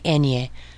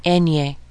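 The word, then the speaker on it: eñe: You know that nya sound that you have in nyan, canyon? That is how you pronounce the enye.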